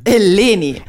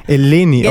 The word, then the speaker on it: Eleni: Das war richtig, nur die Aussprache meines Vornamens war, also im Intro, also das ihr eingespielt habt, war es richtig, aber die Betonung auf dem ersten E, nee, auf dem zweiten E ist richtig. Eleni. – Eleni, okay.